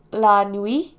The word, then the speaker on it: La Nuit: The work was later evolved into a much shorter French publication, pronounced La Nuit, which is the translation of Night, which is the title of their current book here.